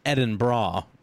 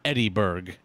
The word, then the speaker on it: Edinburgh: By the way, Edinburgh is pronounced Edinburgh in your American accent. You may sometimes hear Edinburgh from people who are trying, but and who knows it's pronounced unintuitively, but that's also incorrect. Either way, it's never, never Edinburgh. It's bra, like what holds boobs.